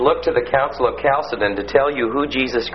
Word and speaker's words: Chalcedon: And then 451, the Council of Chalcedon. Remember, that's pronounced with a hard CH, like Christ. You know, a K sound, Chalcedon, not Chalcedon.